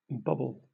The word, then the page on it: bobble: British English